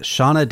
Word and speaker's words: Seona: So honorable mention to Ricky Gervais and his singing career before he started acting with Shauna Dancing. It's spelled S-E-O-N-A Dancing.